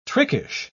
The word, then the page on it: trickish: British English